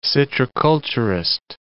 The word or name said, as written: citriculturist